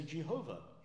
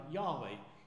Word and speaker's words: Jehovah: And many centuries ago, we used in English, we say Jehovah. Some more modern commentators and scholars think it could be a different pronunciation, like Yahweh.